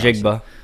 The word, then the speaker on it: Njigba: let's say wide receiver Jackson Smith and Jigma. Is that how you pronounce his name? I'm so sorry if I mispronounced Njigba. – It. Njigba. Let's say he's there in addition to Bijan Robinson.